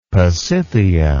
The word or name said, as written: pasithea